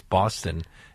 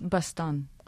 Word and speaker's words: Boston: Now, I'm not sure whether you pronounce it the same as the city in the United States, Boston. It's spelt the same. – It's Bastan.